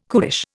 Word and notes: cuish: Indian

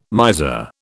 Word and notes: miser: British English

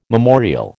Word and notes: memorial: American English

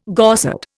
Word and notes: Gorsedd: Indian

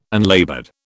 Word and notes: unlaboured: British English

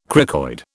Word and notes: cricoid: British English

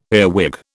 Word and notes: earwig: British English

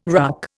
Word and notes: RUC: Indian

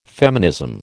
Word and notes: feminism: American English

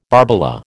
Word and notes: barbola: American English

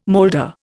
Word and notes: moulder: Indian

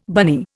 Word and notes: bunny: Indian

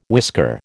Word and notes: whisker: American English

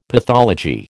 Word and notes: pathology: American English

